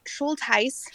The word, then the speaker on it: Schultes: It's actually Schultes. – Schultes. – It's a German name.